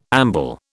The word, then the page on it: amble: British English